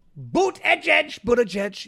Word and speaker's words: Buttigieg: Whether it's Biden, Gavin Newsom, Barack Obama, Kamala Harris, Pete Buttigieg, you can't say, he's got a hard name to pronounce. You know, it's boot. It's a boot, like the sanctimonious, he's got boots. Edge, you know that? That's how you're supposed to pronounce it.